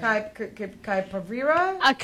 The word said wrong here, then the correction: capybara: Capybara? That's how you say it!